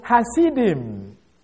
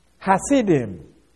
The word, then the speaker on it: Hasidim: Hasidim. H-A-S-I-D-I-M. Although, this word, you may find it some other place, they may have it as C-H, Hasidim. This is because of the hard pronouncing of the the Hebrew, ha. So they can either you use it as H or CH.